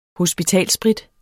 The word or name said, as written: hospitalssprit